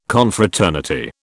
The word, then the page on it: Confraternity: British English